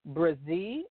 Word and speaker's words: Brzee: That's Shreem Brzee. It's spelled S-H-R-E-E-M. And the next word is Brzee, B-R-Z-E-E.